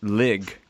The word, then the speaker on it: Ligue: But how is that pronounced correctly? You sort of speak French. – I mean, it's really just Ligue. – Ligue, what's two? – De.